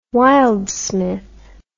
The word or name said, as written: wildsmith